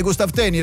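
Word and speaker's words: Gustav Thöni: Gustav Tönni, l'ho detto bene, Gustav Tönni, ragazzi, Gustav Tönni, avevo fatto le prove.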